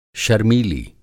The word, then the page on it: शर्मीली: Hindi